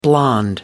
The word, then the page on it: blond: American English